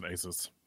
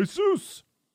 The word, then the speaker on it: Asus: Or how do you pronounce it? Asus or Asus? – I pronounce it Asus. – He's got it Asus. – Right. Asus. – You know, like the God of Thunder.